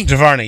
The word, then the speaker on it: DuVernay: Ava DuVernay? – DuVernay, yeah.